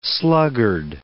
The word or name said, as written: sluggard